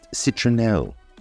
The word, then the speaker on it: citronnelle: In French, lemongrass is pronounced citronelle, and spelt C-I-T-R-O-N-N-E-L-L-E.